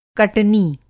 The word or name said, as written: कटनी